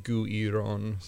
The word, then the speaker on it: Guiron: Going by a Japanese pronunciation, it would be Guiron, so...